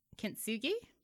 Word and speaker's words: kintsugi: And the psychic tells her about kintsugi. Am I saying that right? – Kintsugi, yeah.